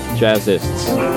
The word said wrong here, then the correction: Jazzist: I added one syllable there.